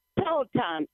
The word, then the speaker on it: Peloton: What prompted you to get the is it Peloton or Peloton? – Peloton is the way I pronounce it.